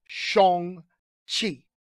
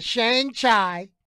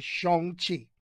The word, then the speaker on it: Shang-Chi: But the correct pronunciation, according to Marvel now, is Shang Chi. Shang Chi. Not Shang Chai, but Shang Chi.